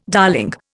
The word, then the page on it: darling: Indian